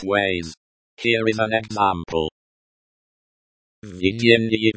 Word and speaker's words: Введение: The v at the beginning of the word vidyennye means in, and vidyennye means leading. The ye at the end of the word vidyennye is pronounced like the English word ye in yes. The stress in the word vidyennye is on the first syllable.